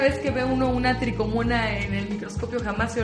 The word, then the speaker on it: trichomona: Que es un parásito que se llama tricomona vaginalis se escribe trichomona. – Es también correcto decirle trichomona.